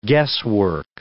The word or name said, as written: guesswork